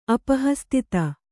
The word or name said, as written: ಅಪಹಸ್ತಿತ